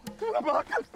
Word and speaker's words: Telemachus: Telemachus.